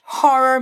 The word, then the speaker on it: horror: Essa palavra, horror, começa com H. Por isso a gente faz o som de h, h. Tipo quando você escreve ha, ha, ha. Repete aí. H. Boa. Na segunda sílaba, tem dois R's. Ou seja, hora de liberar o interior do Paraná ou o interior de São Paulo de dentro de você. Puxa esse R.